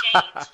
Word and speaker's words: Jaynes: C-H-R-I-S-T-I. I still have to spell that for people. It's Jaynes, J-A-Y-N-E-S, at Christi Jaynes on Twitter, Instagram, and Facebook.